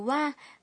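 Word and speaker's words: wa (は): Ngày xưa, trợ từ wa đứng sau chủ đề của câu được phát âm là ha và viết là ha. Phát âm của từ này dần biến đổi thành wa, nhưng cách viết vẫn là ha.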